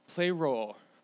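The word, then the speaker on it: Play role (πληρόω): Play role. That's how you say it in the Greek.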